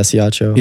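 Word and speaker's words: Ciacco: Ciaccio. I don't know if I'm saying it. C-I-A-C-C-O. I don't know how you would pronounce that. – Well, if he's Italian, then yeah, Ciaccio.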